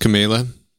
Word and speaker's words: Kamala: It's pronounced Kamala.